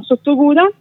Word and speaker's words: Sottoguda: Allora, il nome è giustissimo, appunto dai piedi della Marmolada a Sottoguda.